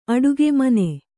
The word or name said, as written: ಅಡುಗೆ ಮನೆ